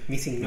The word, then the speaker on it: Missing: es Missing. Missing. No Mr. Mister, sino Missing.